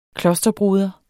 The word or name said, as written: klosterbroder